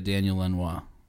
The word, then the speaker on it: Daniel Lanois: When Shu and I are in harmony like that, that's when you get a Daniel Lanois.